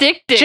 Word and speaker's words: diptych: And they're called diptychs. – Is that how it's pronounced, or is it supposed to be like deek? – No, it's diptych.